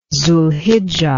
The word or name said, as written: zulhijjah